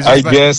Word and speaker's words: Alguer: Ecco, vedi, Alguer senza la R, si pronuncia senza.